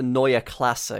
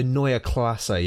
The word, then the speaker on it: Neue Klasse: You got to do like the corporate thing. It's the Neue Klasse, because that's how you say it in German. – Oh, sorry, the Neue Klasse, yes.